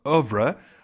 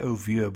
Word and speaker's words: oeuvre: In fact, he expanded on his oeuvre. I wanted to say oeuvre, but I learned in the dictionary you pronounce it oeuvre.